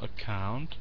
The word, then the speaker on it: account: So the accent on the second syllable. Account.